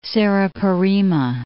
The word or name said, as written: Serra Parima